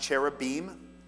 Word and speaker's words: cherubim: It's pronounced cherubim and seraphim. So when we sing cherubim and seraphim falling down before thee, it rhymes better. Don't sing seraphim and cherubim. It doesn't make any sense. It's pronounced im, cherubim. Okay, side note, rant, pet peeve over.